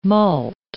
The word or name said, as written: malt